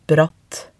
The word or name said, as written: bratt